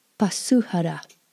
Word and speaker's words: pasûhara: Pasuhara. Pasuhara. Oto. Oto. So if you are Oto, you would introduce yourself as Ta Takita Pasuhara.